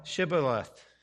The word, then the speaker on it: Shibboleth: And he said Shibboleth, for he could not frame to pronounce it right. So you see that the H is missing there.